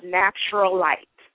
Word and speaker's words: Nappturalite: It's pronounced Nappturalite. – Nappturalite. Nappturalite. – Okay. Mm-hmm. It's a combination of natural, nappy, and elite.